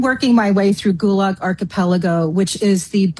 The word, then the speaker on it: Archipelago: Archipelago, and it took me a long time, too.